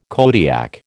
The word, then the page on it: Kodiak: American English